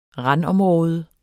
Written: randområde

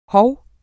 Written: hov

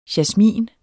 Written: jasmin

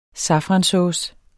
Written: safransauce

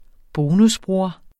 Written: bonusbror

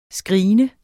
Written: screene

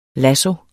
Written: lasso

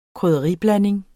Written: KRYDDERIBLANDING

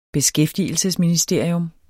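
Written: beskæftigelsesministerie

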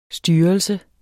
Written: styrelse

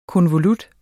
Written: konvolut

